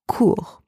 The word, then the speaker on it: cours: In the second word, le cours, spelled C-O-U-R-S, can mean a few different things, but mainly means class or lesson. And it has the same pronunciation as the first one because the final consonant S is silent. And the third word, court, spelled C-O-U-R-T, means short, as in short in length or in time.